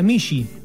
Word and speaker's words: mixi: ¿Vos sabías que en lengua otomí al gato se lo llama mixi? – Mira. – Bueno, no lo sabías, ahora no. Lo sabés. Y se pronuncia michi.